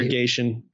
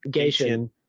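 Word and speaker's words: Gatien: He's working for Peter Gatchian. I'm not sure I'm saying that right, but Peter Gatchian. – Gatchian.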